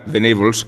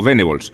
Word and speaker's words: Venables: ahora me Igual que se pronuncia vegetables y no vegetables, es más adecuado pronunciar venables que no venables. Aunque eh, buena parte del debate español se resolvió llamando a Terry Venables, tal cual se leía, venables.